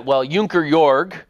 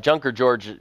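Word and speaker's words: Junker Jörg: Junker Jorg. Junker George would be, of course, the English way to pronounce it.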